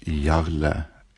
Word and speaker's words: Jarle: While my first name is pronounced Jarle, it is spelt J-A-R-L-E, although with English phonetics, the spelling should have been Y-A-H-R-L-U-H, I think, while Patterson is fairly straightforward, I think.